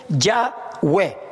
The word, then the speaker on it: Yahweh: Hasta que se estableció el presente consenso académico casi universal que la pronunciación original debe haber sido Yahweh.